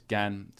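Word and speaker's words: Gan (干): In the front entrance, because it's Gan, so it's or at least this pronunciation of this character, meaning dry, is Gan. So it's out in the first tone, so it's front of the entrance.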